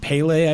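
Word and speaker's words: Pelé: The RGN UK podcast got an email about how a lot of the American podcasts pronounce it as Pele.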